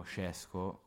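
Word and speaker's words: Šeško: Idem Sesco che, o Scesco, come lo pronunciava ieri Zancan, che dopo avremo ospite, che però andrà già andrà all'Ipsia il prossimo anno, è di proprietà dell'Ipsia.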